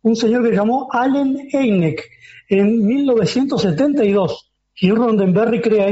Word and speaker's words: Hynek: Hynek.